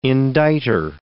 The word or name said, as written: indictor